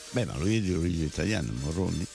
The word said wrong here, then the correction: Mulroney: Non è Morroni, è Mulroney. – Ah, scusa, lo leggi Morroni. – Perché si pronuncia così.